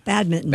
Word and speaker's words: badminton: You don't pronounce that N? – You do not. – No, badminton.